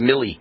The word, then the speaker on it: Milley: Miley, yeah, or don't he pronounce it Milley? I think it's pronounced Milley.